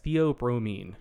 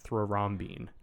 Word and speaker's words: theobromine: So yes, theobromine, not thrombine.